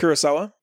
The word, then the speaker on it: Kurosawa: Kurosawa.